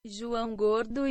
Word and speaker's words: João Gordo: So let's see if Google Translate could pronounce that for me. – João Gordo e Asteroids Trio. – There you go. That's what you get. That's in Portuguese.